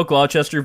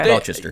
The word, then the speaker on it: Gloucester: You definitely pronounced it wrong there. – They shorten it in this film, too, to just Gloucester. – Okay. – Gloucester.